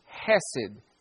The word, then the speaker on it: hesed: I'll spell it for you. C-H-E-S-E-D. It looks like it would be pronounced chesed, but is hesed.